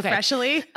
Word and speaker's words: Freshly: It's just Freshly, like the word.